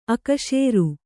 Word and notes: ಅಕ್ಸರ: kannada

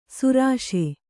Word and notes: ಸರೋಷ: Kannada

